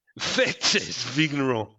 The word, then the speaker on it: Fête des Vignerons: I think I've pronounced that right. – Like a native. – Fête des Vignerons, yeah.